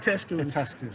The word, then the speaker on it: Itescu: It's Itescu. It's a Romanian last name. – Yep. Itescu.